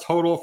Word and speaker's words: HODL: I believe the correct pronunciation is HODL. We've gotten the fact check that it is pronounced HODL for hold on.